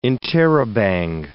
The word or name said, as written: interrobang